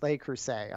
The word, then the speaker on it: Le Creuset: So I think it's pronounced Le Creuset. I remember that from my French class.